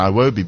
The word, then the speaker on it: Iwobi: He says that both his idols, Cristiano Ronaldo and Alex Iwobi, are his heroes. Did I pronounce it correctly? Iwobi? – Yeah, Ronaldo, yeah. – No, Alex Iwobi, yes, Iwobi, yes, thank you.